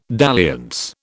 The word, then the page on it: dalliance: British English